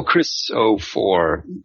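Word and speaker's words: Xophor: Actually, the X in Zophor is pronounced the same as the X in Christmas. So. – Oh, Chris-O-4.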